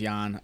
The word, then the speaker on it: Jan: So yeah, like he said, my name's Jan. Looks like Jan, pronounced like you're about to fall asleep.